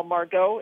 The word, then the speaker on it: Margot: So Manuel Margot, is that how you say his last name? – Yeah, until he tells me otherwise, that's what I'm going with.